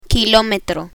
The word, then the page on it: kilometro: spanish